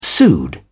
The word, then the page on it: pseud: British English